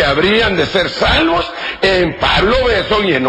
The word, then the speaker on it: Besson: La versión se llama versión Pablo Besson, con dos S's y sin acento en la O, porque si no sería Pablo Besson.